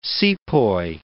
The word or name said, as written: sepoy